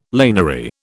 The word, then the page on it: laniary: British English